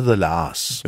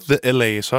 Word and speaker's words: The La's: Er det The Lars eller The LA's? – Det er The Lars. – Jo, jo, men jeg har bare hørt The LA's også.